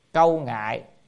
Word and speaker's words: câu ngại: Thường tại thế gian nhi bất vi thế gian sở, chữ này đọc là câu ngại, tức là thường tại thế gian nhi bất vi thế gian câu ngại.